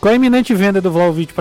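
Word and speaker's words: Vlahović: que com a iminente venda do atacante Vlaudic, Vlaudic, qual é a pronúncia, Caio? Sabe? Vlaudic, Vlaudic, deixa eu, deixa eu abrir o, o microfone. Vlaovic, na verdade. Como é? Vla, Vlaovic. Vlaovic.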